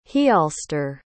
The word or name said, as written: heolstor